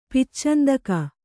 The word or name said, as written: ಪಿಚ್ಚಂದಕ